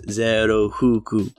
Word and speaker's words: Zero Huku: I haven't read or seen anything, but I think it's funny because in Japanese, the way you would pronounce Zero Fuku's name is Zero Huku.